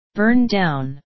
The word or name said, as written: burn down